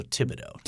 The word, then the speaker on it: Thibodeau: It's a TH to begin the – but is the H essentially silent? – I believe the H is silent. – So Thibodeau.